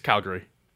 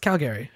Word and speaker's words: Calgary: You know how I like to say Calgary? Calgary. – Calgary? You'll probably get shot there. – Really? Actually, is that not how they like it? – It's Calgary. – It's Calgary.